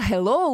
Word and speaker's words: hello: Но если мы посмотрим транскрипцию, то Halloween ударение падает на первый слог, а hello ударение падает на второй слог.